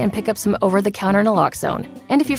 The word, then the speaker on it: Naloxone: For the record, it's Naloxone. Nuh, like, is Naloxone addictive? Nuh. Locks like Naloxone keeps humans safe like locks keep bikes safe, and own like Naloxone can save lives in our very own communities.